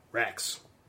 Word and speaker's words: Rax: You assume this is Raz, the guy that sort of... – Rax. – Are you pronouncing the X like a Z? – Wait, I assume Rax is the governor's advisor?